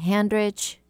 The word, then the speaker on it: Handrich: And my name is Debby Handrich. It's spelled exactly like it sounds, H-A-N-D-R-I-C-H.